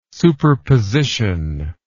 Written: superposition

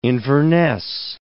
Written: Inverness

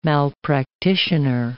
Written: malpractitioner

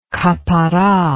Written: kapparah

